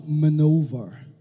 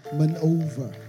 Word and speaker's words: MAN-oeuvre: All right, so you're welcome to MAN-oeuvre. That's how it's pronounced, right? MAN-oeuvre.